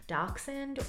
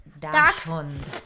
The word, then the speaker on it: Dachshund: She is a wiener dog, but the correct name is Dachshund, or I believe could also be pronounced the German way, which is Dachshund.